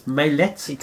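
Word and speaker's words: Maillet: I did actually learn it was Mallette. – He confirmed for me.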